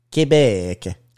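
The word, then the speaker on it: Québec: And then we went to Quebec City. – Quebec. – Saw the fort. – That's how you say it in French.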